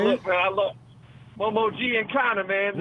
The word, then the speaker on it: Giancana: It's Sam Giancana, man.